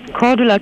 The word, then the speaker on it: Cordula: Mein Name ist Cordula Kine.